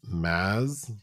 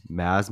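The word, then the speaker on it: Mads: He said it like Maz. – Maz Mikkelsen, so the D is silent? – Maz, kind of, yeah.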